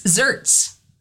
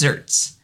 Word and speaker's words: xertz: It's zerts. And it's spelled X-E-R-T-Z, but it's pronounced zerts.